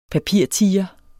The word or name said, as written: papirtiger